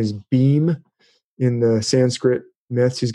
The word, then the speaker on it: Bhima: Bhim is kind of a Hercules figure, Bhima. It's spelled Bhima, B-H-I-M-A. They usually pronounce it Bhim,